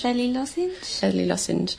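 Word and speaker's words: Veli Lošinj: Day three, and we are now at, how do you say it, Vjela Lozinj. – Veli Lozinj.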